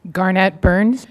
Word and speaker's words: Garnett Burns: Garnett Burns, GarnettBurns.